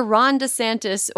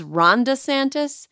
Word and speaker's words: DeSantis: First, Florida Governor Ron DeSantis, or in my head, I use the way that, like, Donald Trump pronounces it, which is Ronda Santis, like the name Ronda.